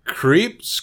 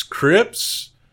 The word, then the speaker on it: Krieps: Vicky Creeps, Crips. It's spelled K-R-I-E-P-S.